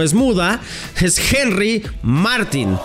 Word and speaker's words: Martín: Tu apellido tiene acento en la I y eso nos confunde mucho porque cualquiera diría, pues güey, le decimos Martín y no pasa nada. Y él me dice, sí, lleva acento, pero se pronuncia Martín.